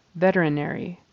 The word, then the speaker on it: veterinary: And veterinary. So the syllable stress is on the first syllable.